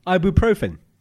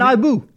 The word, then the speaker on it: ibuprofen: Ibuprofen. – You do ibu. – See, he went ibu. – Ibu. And I did ibu, like a view. Ibuprofen.